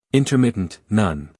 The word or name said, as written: intermittent